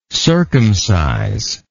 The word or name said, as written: circumciser